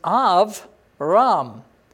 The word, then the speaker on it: Abram: And without that dot, it's v as a V sound. So when we call him Abraham and Abram, we're basically saying the Jewish pronunciation of it. Okay, but when you see Abram, Abram, the word that you see before you in these verses called Abram, it really means Av Ram.